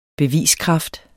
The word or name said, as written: beviskraft